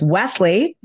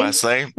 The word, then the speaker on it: Wesley: So while her, you know, marriage may have did with Paul, I believe it's Wesley, not Weasley. – Oh, Wesley.